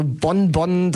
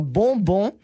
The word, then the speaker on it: Bonbon: Also Bonbon, das ist schon richtig falsch ausgesprochen. Du musst es perfekto français aussprechen und zwar Bonbon.